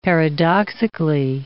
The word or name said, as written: paradoxically